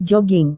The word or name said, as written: joging